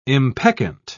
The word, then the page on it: impeccant: British English